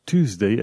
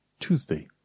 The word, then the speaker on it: Tuesday: după aia Tuesday ai zice că e tu. Deci el se pronunță de fapt cu ciu. Tuesday.